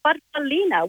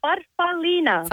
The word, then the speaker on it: farfallina: Well, growing up, as we're little girls, Mum called it a farfallina, which in Italian means a little butterfly. – Oh. Older, how do you say it? – Farfallina.